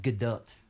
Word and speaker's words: Gadot: I always thought it was Gal Gadot, but it's not. – That's what everyone says. – Yeah, but it's not. – Is it actually Gail or something like that? No, it's Gal. – Okay. – But it's like Gadot.